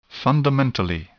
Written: fundamentally

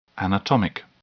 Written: anatomic

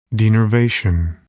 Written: denervation

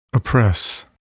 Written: oppress

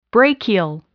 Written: brachial

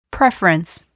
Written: preference

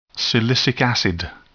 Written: silicic acid

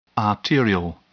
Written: arterial

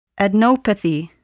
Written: adenopathy

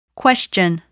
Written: question

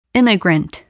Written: immigrant

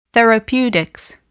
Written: therapeutics

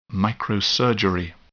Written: microsurgery

